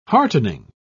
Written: heartening

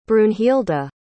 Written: brunhilda

